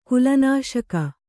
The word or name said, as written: ಕುಲನಾಶಕ